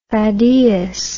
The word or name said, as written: thaddaeus